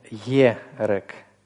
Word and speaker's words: ерек: Нет, ерек. Ерек. То есть там нет отдельного слова «и» или гласный «и». Да? Там есть «йод», который соединяется с гласным «сиголь». Ерек. И значок ударения стоит вот на этом первом слове.